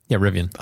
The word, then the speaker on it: Rivian: But well, I think that the Rivian is one. Did I say that properly? – Yeah, Rivian.